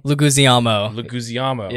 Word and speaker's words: Leguizamo: John, I can never pronounce his name. Leguizamo? – Leguizamo. Yeah.